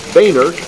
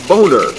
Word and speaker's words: Boehner: As few Americans know, the leader's name is pronounced Boehner, not Boner,